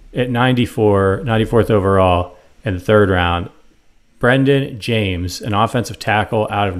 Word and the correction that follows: Jaimes: And so when I pull up this guy's scouting report, pronounced on Hymus. – Is it Hymus? – Brendan Hymus.